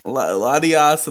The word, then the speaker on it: Latias: Latias and Latios.